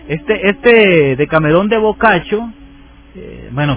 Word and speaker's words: Boccaccio: Giovanni Boccaccio. Boccaccio se escribe B-O-C-C-A-C-C-I-O. Lleva 4 C, lleva su nombre. Boccaccio.